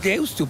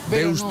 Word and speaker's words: Deusto: Es Deusto, porque se escribe con U final. – Deusto.